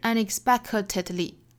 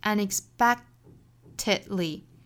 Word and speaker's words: unexpectedly: 那最后这个 unexpectedly 中间的这个 k 的发音也要注意，它其实是一个失去爆破了，不是 unexpectedly，应该是 unexpectedly unexpectedly。